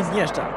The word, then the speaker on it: Iniesta: Il donne à son camarade Titoun. – Titoun. Titoun, vous dites. Il faut bien prononcer. – Iniesta. C'est pas ça. – J'ai dit Iniesta.